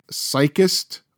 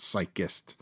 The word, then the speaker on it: Psychist: And I hope I pronounced this right. Psychist Or, yeah, I think that's it. Psychist. It's P-S-Y-C-H-I-S-T. Psychist.